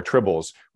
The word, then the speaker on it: tribble: And tribble rhymes with trouble.